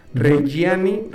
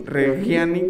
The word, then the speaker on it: Reggiani: Entonces, fíjate que Venus Miria, este también portal muy famoso, sacó una foto de Lady Gaga y Adam Driver personificando a Patricia Regani, Reggiani, Reggiani, como se pronuncia, y Mauricio Gucci, güey.